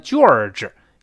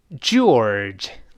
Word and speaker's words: George: 哎，首先上来这个字母 G，这里发 J J，不要读成 G 七西那个 G。那我们多次说过，在英语中没有 G 七西这样的发音，这是 J J，所以不要读成 George，一听好像像卷纸一样，那不是卷纸啊。我们大声再读一遍。 George, George，